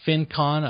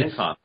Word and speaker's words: FinCon: You created the FinCon. I don't know if I pronounced it correctly. – FinCon.